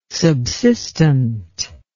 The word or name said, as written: subsistent